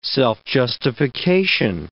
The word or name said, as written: self-justification